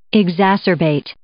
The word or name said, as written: exacerbate